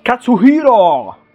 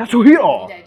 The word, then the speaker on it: Katsuhiro: Katsuhiro! Devi dirlo bene. – Cioè, ragazzi, io mi scuso perché in realtà... – Katsuhiro! Ma devi mettere l'accento su Hiro! – Ecco, hai capito? Ci devi mettere l'enfasi quando... Katsuhiro! Perché altrimenti italianizzi troppo e non va bene.